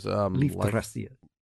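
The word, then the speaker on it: Leifthrasir: Leaf-thracia.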